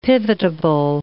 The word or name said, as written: pivotable